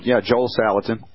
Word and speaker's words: Joel Salatin: or whether you agree with Joe Salatin, I might have mispronounced that. – Joel Salatin.